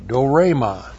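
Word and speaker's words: dorema (δώρημα): D long O R long E M A. Dorema.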